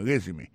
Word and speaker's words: resume: I see from your resume, moi, ouais, dans CV ou dans curriculum vitae ou, regardez qui j'ai prononcé mon résumé. I see from your resume, accent tombé sur le premier syllabe là.